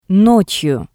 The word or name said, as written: Notsyu